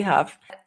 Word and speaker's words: Hough: I think it's just Abby Huff. And my name, and spell Huff, my last name is spelled H-O-U-G-H like Julianne Huff.